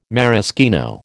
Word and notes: maraschino: American English

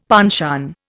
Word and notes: penchant: Indian

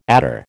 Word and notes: adder: American English

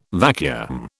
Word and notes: vacuum: British English

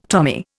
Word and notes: Tommy: Indian

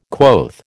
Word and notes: quoth: American English

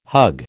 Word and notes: hug: American English

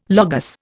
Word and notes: Logos: Indian

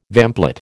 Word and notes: vamplate: American English

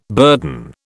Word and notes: burden: British English

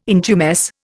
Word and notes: intumesce: Indian

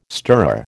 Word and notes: stirrer: American English

